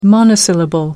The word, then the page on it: monosyllable: American English